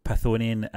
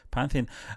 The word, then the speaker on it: Pantheon: Because of the events that unfolded in Thor Ragnarok, Marvel may need to introduce Hercules and the Greek Pythonian into the MCU. Did I say that right? And Thor Love and Thunder. And the third installment in the Thor franchise. – Pantheon.